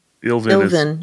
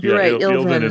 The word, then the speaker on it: Illvin: Which one? Ilvin or Arhis? That's how I pronounce it. Ilvin. – Ilvin is how I say that. Is I-L-L-V-I-N.